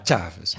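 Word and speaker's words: Chávez: In 1998 werden die gewonnen door Hugo Chavez, een man, zeg je dat hij goed, Chavez? – Chavez. – Ja, maar de klemtoon wel op A, hè? Ja. Chavez.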